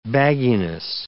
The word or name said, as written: bagginess